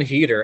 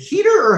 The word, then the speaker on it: Heder: Well, so like, Heder, is it Heder or Hader? I might be, as always, I might be mispronouncing things. – I thought it was Heder. – Yeah, let's say Heder for the sake of a baseball movie.